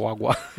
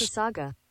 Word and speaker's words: Mississauga: Mississauga.